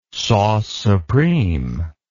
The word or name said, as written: sauce supreme